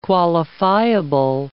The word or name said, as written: qualifiable